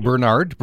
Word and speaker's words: Burnard: Am I pronouncing that last name correctly? – It's Burnard. – Burnard, okay. – Pretty close, yeah.